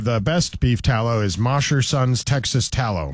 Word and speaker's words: Mosher: I wouldn't know, but Mosher, because mosh pit, MOSHER.